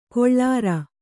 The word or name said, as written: ಕೊಳ್ಳಾರ